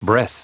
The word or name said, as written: breath